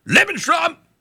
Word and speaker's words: Lebensraum: When Germany began to rearm and seek Lebensraum, gotta say it like a Kraut, Lebensraum, aggressively in the late 1930s, the Roosevelt administration cooperated closely with the Brits and the French in measures to oppose German expansion.